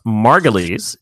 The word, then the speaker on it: Margulies: I am going to read off the answers from lowest to highest, but first I'm going to say it's pronounced Margulies, Juliana Margulies.